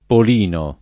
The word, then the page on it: Polino: lingua italiana